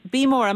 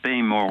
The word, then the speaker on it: Beemore: Beemork, am I pronouncing that correctly? – We call it Beemore. – Ah, you see, there's always a local pronunciation.